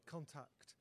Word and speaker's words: Kontakte: The name of the sort of title story, Contact, isn't spelt C-O-N-T-A-C-T. It's spelt in a German way, K-O-N-T-A-K-T-E, pronounced exactly the same way.